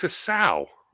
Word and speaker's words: sow: S-O-W. Look it up. Sow. A sow, animal. Female pig. The verb sow is pronounced completely different from the noun sow, which means a female pig.